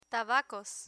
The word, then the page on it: tabacos: Spanish